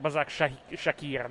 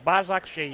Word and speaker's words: Başakşehir: Başakşehir, non è Başakşehir, non c'è la K, è Başakşehir.